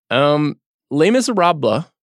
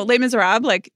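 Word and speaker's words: Les Misérables: Am I saying that correctly? You're the French master on this show. – Yeah, that was good. – Okay. How would you say it? – I'm not actually French. So Les Miserables, you don't have to do the blah, but...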